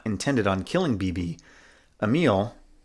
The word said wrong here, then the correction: Amelie: right? Is that how you pronounce it? – Amelie. Yep.